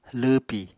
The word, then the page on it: lwwpi: apatani